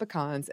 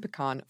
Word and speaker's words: pecan: Being that we are in Little Rock, Arkansas, most people who order it say it the southern way, pecan. However, when we get the rare person who pronounces it the northern way, pecan, and has to listen to me pronounce it pecan, they usually fit into one of a few categories.